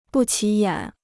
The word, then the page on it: 不起眼: bù qǐ yǎn